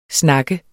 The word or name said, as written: snak